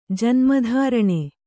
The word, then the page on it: ಜನ್ಮಧಾರಣೆ: kannada